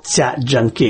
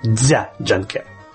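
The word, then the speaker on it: Jia Zhangke: Саш, вы знаете, я с большим интересом слежу за китайским режиссером Ця Джанке, или еще есть транскрипция произношения Дзя Джанке.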